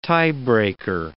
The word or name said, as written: tiebreaker